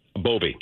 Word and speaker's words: Bove: Emil Bove? – Bovey.